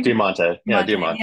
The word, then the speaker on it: Diamante: Diamante.